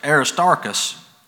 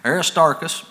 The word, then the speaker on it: Aristarchus: And the whole city was filled with confusion, and having caught Gaius and Aristarchus, is how that's pronounced, keep muttering that up, but Aristarchus, men of Macedonia, Paul's companions in travel, they rushed with one accord into the theater.